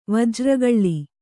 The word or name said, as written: ವಜ್ರಗಳ್ಳಿ